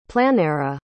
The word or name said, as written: planera